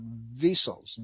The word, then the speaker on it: VSELs: They're called vessels, V-S-E-L-S. I think I pronounced it properly, but that's how you spell it, V-S-E-L-S.